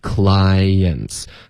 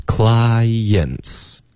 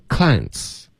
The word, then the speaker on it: clients: Till I get back some 跟 of 呢会连读 some of，然后这个 v 的音就给省了 summer Till I get back some of my massage clients，大家也注意它是两个音节 OK， clients 两个音节 clients，别发成 clients， OK，这一个音节的发音呢，对方老外会听不懂的，